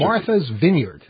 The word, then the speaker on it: Martha's Vineyard: Martha's Vineyard. That's the way to say it.